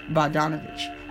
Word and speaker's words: Bogdanovich: Bogdanovich.